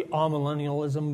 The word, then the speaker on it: amillennialism: I've heard it pronounced both amillennial and amillennial. It's like tomato, tomato. I prefer to say amillennialism, but if you prefer amillennial, that's fine.